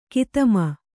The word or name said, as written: ಗೌತಮಿ